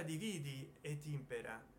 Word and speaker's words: divide et impera: Il titolo è, l'ho scritto prima nella forma classica, divide et impera con tutti gli accenti, che si pronuncia dividi et impera, ovvero sia come ci go ver a qua la tastiera virtuale governa no meglio.